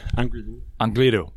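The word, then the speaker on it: Angliru: Angrilu, am I pronouncing that right? – Angrilu. Otherwise known as Angry Lou.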